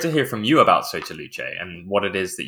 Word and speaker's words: Sotto Luce: No, that's correct. It's Soto Luce. It's Italian, and you got it right.